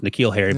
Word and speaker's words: N'Keal Harry: I did the same thing with N'Keal Harry, which is N'Keal Harry, but he turned out not to be great, so we can call him whatever you want, I think.